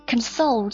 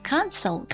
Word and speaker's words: console: 我们先看第一个 console，作为动词它是表示安慰慰藉的意思，如果它作为名词呢，读音会变叫 console。